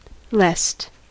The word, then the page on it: lest: American English